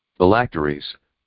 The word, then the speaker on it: phylacteries: Phylacteries.